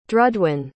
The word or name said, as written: drudwyn